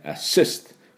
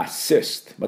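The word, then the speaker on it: assist: アシストされたと、助けられたって意味ですけどもね、これ両方言っていきたいと思いますが、最初のアはね、今度は逆に半ボインでアシスト、ここのところがアクセントがあります。だからアシスト、またはアシステッド、アシステッド、こういうところで、弱い強いを、極端にやってください。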